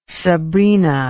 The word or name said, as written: Sabrina